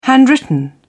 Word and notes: handwritten: British English

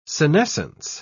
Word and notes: senescence: British English

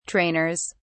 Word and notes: trainers: British English

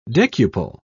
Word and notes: decuple: British English

